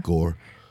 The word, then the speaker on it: Gorr: As G-O-R-R. How do you say that? Gurr? – G-O-O? – G-O-R-R. Gore? – Yeah, Gore.